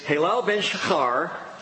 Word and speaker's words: Hillel ben Shachar: But the correct pronunciation is Hillel ben Shachar, son of the dawn.